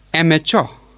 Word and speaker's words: amateur: It is pronounced as amateur. Amateur. And the stress falls on the first syllable.